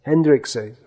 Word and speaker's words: Hendrikse: The Dutch Protestant Church began an investigation when the cleric, Klaas Hendrikse, is that pronunciation okay? Hendrikse? Hendrikse. Okay, we have a real live Dutchman with us to correct our Dutch pronunciation here.